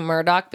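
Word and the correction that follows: Murdaugh: because it's Murdaw.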